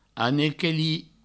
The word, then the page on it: anequelir: anequeli